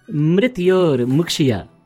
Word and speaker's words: Mrityor Mukshya: Mitror Mushki. – Mitror Mushki. No, it is Mrityor Mukshya. Mrityor. Yes. Mrityor.